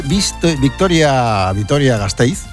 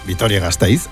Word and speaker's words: Vitoria-Gasteiz: Descubre Vitoria-Gasteiz este verano. A ver si lo digo bien, Vitoria-Gasteiz, ahora sí.